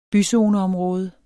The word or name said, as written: byzoneområde